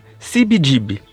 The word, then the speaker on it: CBGB: Aqui a gente vai usar a pronúncia em inglês, que é mais fiel à história, sibidib.